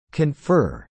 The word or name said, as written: confer